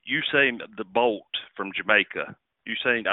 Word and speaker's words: Usain: Usain Bolt, apparently, is the correct pronunciation.